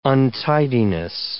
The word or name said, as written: untidiness